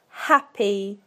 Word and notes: happy: British English

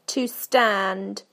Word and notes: to stand: British English

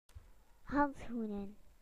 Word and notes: gloves: British English

